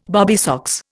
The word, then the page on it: bobby socks: Indian